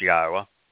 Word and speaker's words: Yahweh: His name is pronounced Yahweh. It's exceedingly easy to figure out how to pronounce it. In fact, if you can say Torah, you can say Yahweh.